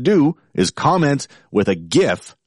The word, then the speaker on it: GIF: Comment on that post with a GIF, hard G, GIF,